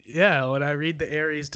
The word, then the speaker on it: Aries: I'm an Aries. Yeah, I knew you were. – You still said it wrong. It's just Aries.